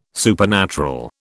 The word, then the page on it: supernatural: British English